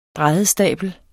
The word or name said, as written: bræddestabel